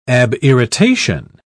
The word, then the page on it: abirritation: British English